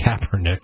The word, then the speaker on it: Kaepernick: And to be clear, that protest began with Colin, and I can never pronounce Kaepernick. – Kaepernick.